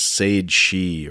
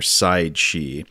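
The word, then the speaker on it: saighead sìth: and then this is something that is spelled like uh, like it looks like Saghead Sith, but I believe this is Sage She or Side She, which is Scots Gaelic for fairy arrow.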